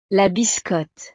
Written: la biscotte

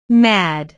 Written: mad

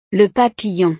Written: le papillon

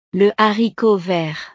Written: le haricot vert

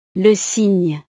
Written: le cygne

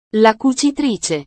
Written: la cucitrice

